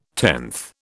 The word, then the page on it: tenth: British English